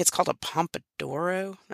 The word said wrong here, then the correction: Pomodoro: I'm probably saying it wrong. Pomodoro.